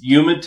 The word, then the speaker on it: humid: It's very humid, actually, on the East Coast. Yep. – It's humid to you and humid to me. – I know. No H pronunciation.